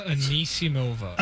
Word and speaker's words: Anisimova: And then reporters on the scene say Amanda Onismova. How do I say it again? – Amanda Anisimova.